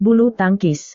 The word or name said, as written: bulu tangkis